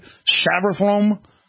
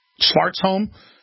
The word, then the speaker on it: Svartholm: No, the next name, Gottfried Savarthrom, found guilty in hacking trial. Svartholm.